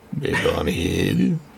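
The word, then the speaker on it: Le Bonheur: Like, we have to double, triple, quadruple down on the Agnes Varda movie, La Barre. No, Le Bon Hill. How do you say it? I don't know. – It's closer to the second way you said it. – Okay. Le Bon Hill.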